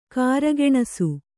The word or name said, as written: ಕಾರ್ಗೆಣಸು